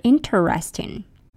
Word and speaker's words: interesting: But do you say interesting or interesting? INTERESTING. It's interesting, not it's interesting. Interesting is an interesting word. Interesting, three syllables, not interesting, four syllables.